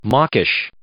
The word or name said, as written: mawkish